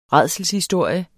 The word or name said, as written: rædselshistorie